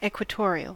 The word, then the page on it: equatorial: American English